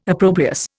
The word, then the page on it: opprobrious: Indian